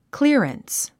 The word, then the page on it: clearance: klí ə rəns; クィアランス